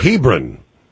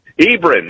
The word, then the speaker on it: Hebron: How do you say that out there? – Hebron. – Hebron, that's it.